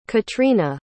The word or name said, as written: katrina